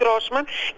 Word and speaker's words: Γκρόσμαν: Προφέρεται Γκρόσμαν και βγαίνει βέβαια από το γερμανικό Gross, ο μεγάλος.